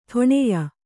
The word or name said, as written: ಠಾಣ್ಯ